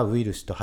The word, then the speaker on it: ウイルス: 日本ではウイルスと発音しているんですが、これ書き方はバイラスって書いてあるんですよね。